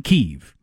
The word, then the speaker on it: Kyiv: Russians call it Kyiv. The country of Russia believing Ukraine belongs to us. Ukrainians call it Kyiv. So if the Ukrainians pronounce it Kyiv and only the invading army that is willing to kill them to subjugate them calls it Kyiv, I'm going with Kyiv.